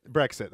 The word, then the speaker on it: Brexit: They call it Brexton? Is that what that's called? Am I saying that wrong? – Brexit? – Brexit, that's what it is.